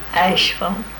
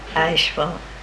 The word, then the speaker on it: Asheville: Asheville. – That's how you say it. – Asheville.